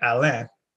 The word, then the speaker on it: Alain: Well, if it was, you know, the true French pronunciation would be Alain, because it's with an I, and that's Alain, that's Alan, mm-hmm.